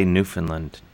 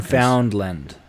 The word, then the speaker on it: Newfoundland: You don't say Newfoundland like us Americans. – Newfoundland.